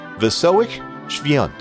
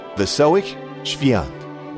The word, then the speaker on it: Wesołych Świąt: And by the way, if you've seen this in text, if you've seen it printed, it doesn't sound anything like it looks if you're used to reading English. It's pronounced Wesołych Świąt. Here it is again. Wesołych Świąt. Or at least I think that's a close approximation.